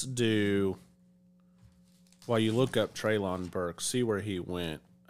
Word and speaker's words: Treylon: Um, is I'm pronouncing it Trayvon or Traylon, Traylon, Treylon Burks.